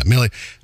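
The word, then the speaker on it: Milli: Yeah, Millie.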